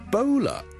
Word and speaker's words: Ebola: 最近咧，如果大家有睇国际新闻咧，都应该会留意到 Ebola 呢个伊波拉病毒咧，喺某啲地方肆虐咁样。 Ebola 伊波拉病毒咧，我哋虽然广东话我哋译做伊。波拉波啦，我哋系 o 一个发音，但如果英文咧，我哋通常咧系读 ebola 嘅 ebola bow bow，好似弓箭嘅弓嗰个 bow，OK，、 okay?